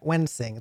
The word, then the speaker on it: Wensing: So I'm talking to Matt Wensing.